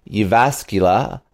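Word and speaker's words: Jyväskylä: Uh, he teaches digital media, media, to executives at Rutgers University, uh, the Irish Management Institute Institute and the University of Jyvaskyla.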